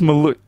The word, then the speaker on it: Molloy: Pronounce it properly, Dave. It's Molloy. Kennedy Moulton.